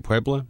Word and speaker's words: Puebla: I believe you pronounced that last name, P-U-E-B-L-A. Puebla, is that right? – Puebla.